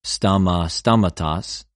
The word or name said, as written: στομα, στοματος